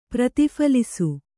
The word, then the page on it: ಪ್ರತಿಫಲಿಸು: prati phalisu